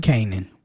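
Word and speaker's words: Kanan: Is it Canaan? – Canaan.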